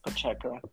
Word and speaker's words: Pacheco: Pacheco.